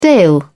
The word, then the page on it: dale: British English